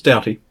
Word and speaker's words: doughty: How do you pronounce that word? – I think it's doughty. – Doughty? Okay.